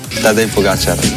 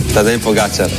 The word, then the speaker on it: Tadej Pogačar: Juanma dice que es aguda, mientras que otros esdrújula, así que aquí tenemos cómo se dice de verdad. – Tadej Pogačar. Tadej Pogačar. Tadej Pogačar.